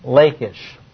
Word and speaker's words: Lachish: They had a problem at one particular site, the site, I'll use the English pronunciation, Lachish.